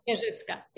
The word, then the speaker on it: Wierzycka: I'm pronouncing that correctly, do I, Magda? – Wierzycka, yeah.